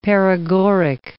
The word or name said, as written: paregoric